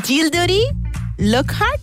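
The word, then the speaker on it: Gilderoy Lockhart: Gildery Lockhart. – Sí. – Pero lo he dicho bien.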